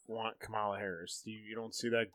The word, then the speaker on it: Kamala: However you say her name. – I believe it's Kamala.